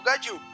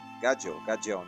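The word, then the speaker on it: Gajeel: É que o Gadil, se você levar pro lado de steel, que é aço, em inglês, você consegue pronunciar legal.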